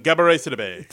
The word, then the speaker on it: Gabourey Sidibe: Gabourey Sidibe.